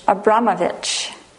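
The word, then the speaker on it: Abramović: And I was thinking about somebody that I don't know if you've heard of, and I don't know if I say her name correctly, but it's Marina Abramovic. Yes? – Abramovich. – Marina Abramovich.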